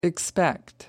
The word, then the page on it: expect: American English